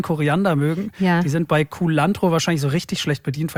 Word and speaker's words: Culantro: Hier also jetzt nochmal ganz kurz Mario mit der übrigens viel schöneren Aussprache als meiner von Culantro. – Culantro. – Culantro.